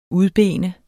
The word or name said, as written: udbene